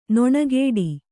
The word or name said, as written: ನೊಣಗೇಡಿ